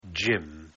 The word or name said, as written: gym